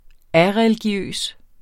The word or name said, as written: areligiøs